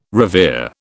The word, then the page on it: revere: British English